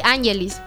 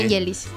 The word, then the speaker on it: Angelis: Pero se le hacía Angelis. Yo le, o sea, no, ahorita que yo lo pronuncié con ang, es para aclararle que va más fuerte ahí. No lleva tilde, pero es Angelis.